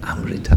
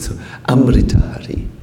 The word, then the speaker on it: Amrita Hari: No, we say we are mindful of Sanskrit. So Amrita Hari. Like this. Amrita Hari. Yes. Not Amrit is a lazy version. Amrita Hari. Huh? Short A.